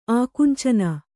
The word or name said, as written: ಆಕುಂಚನ